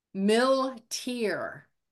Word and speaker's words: Milteer: Milteer.